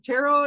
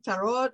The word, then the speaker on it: tarot: Is it tarot? How do you pronounce it? – Yeah, alternatively, tarot or tarot. I grew up hearing it pronounced as tarot, but I'm also from the South, so I kind of take that with a grain of salt.